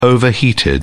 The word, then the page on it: overheated: British English